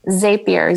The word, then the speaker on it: Zapier: Zapier, Z-A-P-I-E-R.